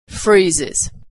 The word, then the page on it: freezes: Iz